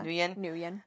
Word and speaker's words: Nguyen: Is that how you say his name? – I think it's Nguyen, yeah. – Nguyen.